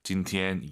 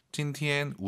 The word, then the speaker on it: 今天: 일성 발음하실 때 저렇게 하셔야 돼요. 선생님처럼 今天 이게 아니라 今天 우리가 말할 때 하는 음정이 아니라 이 음정.